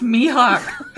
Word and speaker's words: Mihawk: And every time he said my hawk, my brain screamed, it's pronounced Mihawk.